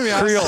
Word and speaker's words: Creel: C-R-E-E-L. – Creole. I say it like Creole, but you say it definitely like I'm straight from the Cajun South.